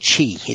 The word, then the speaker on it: Qi: So these Q devices, QI, is that how we say it? I think it's called Qi. It's pronounced Qi.